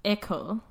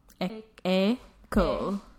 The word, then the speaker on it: École: Oh, is that how you pronounce it? – Yes, École. École. Yep.